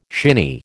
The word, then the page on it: shinny: American English